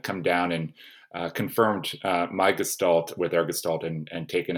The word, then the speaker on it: gestalt: I will also tell you that I am using the British enunciation of gestalt with a hard G.